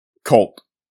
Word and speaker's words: CVLTE: Number 4, Cult. And that's spelled C-V-L-T-E for anyone who's not familiar with them.